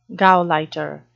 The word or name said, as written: gauleiter